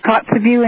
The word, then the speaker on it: Kotzebue: And evidently in Kotzebue, that's how you say it? – It's Kotzebue indeed. There is no road from Fairbanks to Kotzebue.